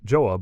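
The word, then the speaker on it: Gjøa: Almost 60 years after Franklin's expedition, in the summer of 1903, a small 45-ton fishing vessel named the Joa, pronounced Joa by North Americans and Joa by Norwegians, set sail from Oslo under the midnight sun in another attempt to finally traverse the Northwest Passage.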